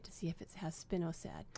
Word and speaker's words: Spinosad: It's called Spinosad or Spinosad. – Let's spell it. – It's S, capital S-P-I-N-O-S-A-D.